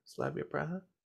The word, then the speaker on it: Slavia Praha: Slavia Praha? – Yeah.